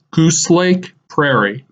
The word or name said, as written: Goose Lake Prairie